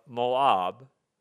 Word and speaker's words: Moab: Why is he mispronouncing Moab? Did anybody think that for a moment? Moab? Maybe you thought, well, maybe that's the correct Hebrew pronunciation, and, you know, maybe I was wrong. And it might be. But, you know, typically we say Moab.